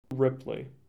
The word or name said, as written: Ripley